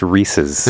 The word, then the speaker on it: Reese's: There you go. It's Reese's.